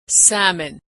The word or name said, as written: salmon